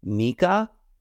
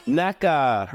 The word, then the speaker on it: Neka: I want to say it's Nika, N-E-K-A.